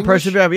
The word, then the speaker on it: persevere: Am I saying that right? Is that correct? – You are persevere. Yes, it is perseverance.